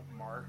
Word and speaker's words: Marc: It's my name, Mark. Remember, I spell it M-A-R-C.